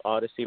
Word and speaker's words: Odyssey: Our name is Odyssey. It's pronounced Odyssey, like the Odyssey, the book.